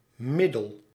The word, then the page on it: medicine: British English